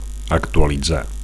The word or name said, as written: actualitzar